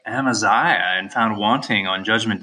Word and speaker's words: Amaziah: Amaziah? – Amaziah.